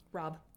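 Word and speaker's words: Robb: Rob with two B's. This last B is silent, just like comb.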